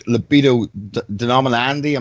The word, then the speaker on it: Libido Dominandi: I think it's from your book, Libido Dominandi. Am I pronouncing that properly? – Libido Andy, yes.